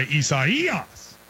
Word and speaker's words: Isaias: People are saying, you placed the syllable on the emphasis on the wrong syllable. It goes on the third syllable. So it's Isaias. So I want to say Isaias.